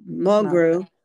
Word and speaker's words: Mulgrew: Mulgrew, I don't know if I'm pronouncing it right. – Mulgrew.